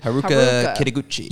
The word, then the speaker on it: Haruka Kitaguchi: Haruka Kitaguchi.